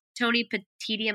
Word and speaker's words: Tony Petitti: And it kind of also feels like that the Big Ten commissioner, who it's Tony Petitti, am I saying it right? – Yes. Yeah, it's okay. Tony Petitti.